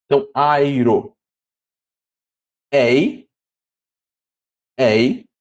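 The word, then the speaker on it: Ei (εἰ): Se pronuncia ei. Então, veja que esse epsilon, ele muda para o som de e, por causa do ditongo. Então, fica como, por exemplo, na palavra lei. Então, não fica ei o som. O som fica de ei. Então, esse aqui há uma mudança na pronúncia do epsilon, que de aberto passa a se tornar fechado.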